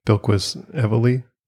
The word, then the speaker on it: Bilquis Evely: Bilquis Evely. There's no R in there, so I'm pretty sure that's how it's pronounced.